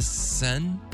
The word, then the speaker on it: Seine: Thames and the Seine? – Mm-hmm.